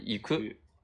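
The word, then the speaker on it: 行く: 嗯好，再往下面的话呢，是一些动词啊，这个是一个是 deru 啊，是出去啊，第二个是 iku 啊。那有些同学说，哎，这个单词是不是可以念成 yuku 啊？啊，是可以念成 yuku 的啊。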